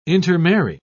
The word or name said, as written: intermarry